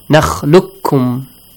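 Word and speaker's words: Nakhlukkum (نخلقكم): There is the qaf. After the qaf, you have kaf. Since these two letters kind of pronounce the same, the same articulation points they have, similar letters they are, okay? You merge them. Nakhluq. Kum. You don't pronounce the letter qaf. You double the kaf. You merge qaf to kaf. Nakhlukkum. Alright?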